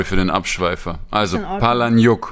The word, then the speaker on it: Palahniuk: Im Englischen sagt man Palahniuk, als wären das zwei Namen, Paula und Nick.